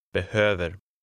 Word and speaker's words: behöver: The stress is on the second syllable. – Behöver.